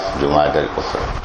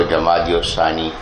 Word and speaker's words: جمادی الاخری: عام طور پر ہم اس کو جمادی الثانی کہہ دیتے ہیں جو تلفظ تو درست نہیں, یہ ہے جمادی جیم کے پیش کے ساتھ جمادی الاخری جمادی الاولی جمادی الاخری.